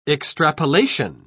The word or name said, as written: extrapolation